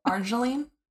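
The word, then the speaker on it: Argireline: Maybe Argerline, tell me how to pronounce that. – Argerline. – That sounds right.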